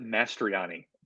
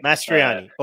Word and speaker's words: Mastroianni: I pronounce it Mastriani. Mastriani.